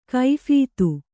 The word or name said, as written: ಕೈಫೀತು